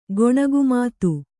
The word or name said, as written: ಗೊಣಗುಮಾತು